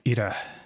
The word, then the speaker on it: Ira: Yeah, the way you pronounce my name is Ida. Bit of a rolled, it's a Maori Ida. Name. Yes. – As in IDA, just IDA. – But spelled IRA, but Ida.